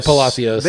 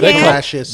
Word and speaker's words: Palacios: Or you could say Palacios. – No, you can't say Palacios. – They pronounce it Palacios.